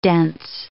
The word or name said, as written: dense